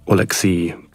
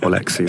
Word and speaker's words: Alexei: Technically, it's like Alexei, but I mean, I think Alexei is fine.